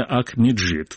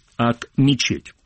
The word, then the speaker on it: Ак-Мечеть: Ак-Меджит. В русском произношении, в русском написании это звучит как Ак-Мечеть.